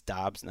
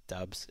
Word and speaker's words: Doubs: Like, if Doubs is starting, and I did learn over the last week it's Doubs, not Dubs, and you guys have been saying it right. Apparently I was saying it wrong.